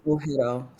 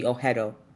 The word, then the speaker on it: Oghedo: Name is Oghedo. Silent, you can say silent G, Oghedo.